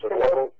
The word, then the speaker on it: synovial: It says LF, that's his terminology, which means lactoferrin in chemical terms, is also a major bioactive component of the synovial, is that how it's pronounced? Synovial fluid? – Synovial.